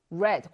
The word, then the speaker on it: read: 앞에 이 read가 아니라 뭐라고 읽어준다? read. read. red. 과거형 red라고 읽어준다.